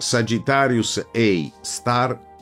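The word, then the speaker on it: Sagittarius A*: Quello posto al centro della Via Lattea, denominato Sagittarius A asterisco, che si pronuncia Sagittarius A star, ha una massa equivalente a circa 4 milioni di soli e si trova a 25.000 anni luce di distanza dalla Terra.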